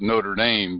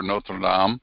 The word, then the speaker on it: Notre Dame: In English, we call it Notre Dame, but we've butchered it because that's the English. The French, of course, sounds much better, Notre Dame.